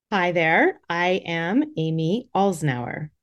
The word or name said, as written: Amy Alznauer